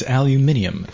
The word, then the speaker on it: aluminium: We have KWD68 says, does it make the shot safer if you pronounce aluminum like the British do? That's right. It's aluminium.